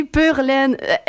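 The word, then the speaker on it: pure laine: Pur laine? – Oui, pur laine.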